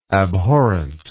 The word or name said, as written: abhorrently